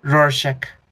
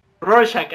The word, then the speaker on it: Rorschach: Pero bueno, eh, entonces, eh, el Doctor Manhattan, eh, este mm, Rorschach, y bueno, es que siempre lo digo mal, ¿cómo se dice? – Rorschach. – Rorschach, ándale.